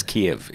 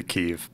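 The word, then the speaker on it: Kyiv: I guess it's Kiev. It's the Ukrainian pronunciation of it. – Yeah, if you want to be very Ukraine-focused, you would say Kiev.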